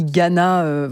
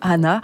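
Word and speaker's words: Għana: On écrit Ghana, enfin voilà pour prononcer à la française, mais on dit Hana.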